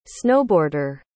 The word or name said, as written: snowboarder